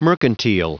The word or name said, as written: mercantile